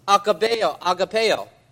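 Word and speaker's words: agapaō: And so in the Greek, it is agapeo, okay? Or, Brother Larson, am I saying that right? Okay, I'm saying it right.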